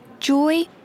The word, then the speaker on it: Joy: Joy Boscom. – How do you spell that? – J-O-Y-B-O-S-C-O-M-B-E.